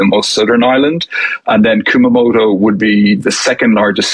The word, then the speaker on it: Kumamoto: Kumamoto. – Kumamoto, excuse me.